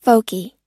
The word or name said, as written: folkie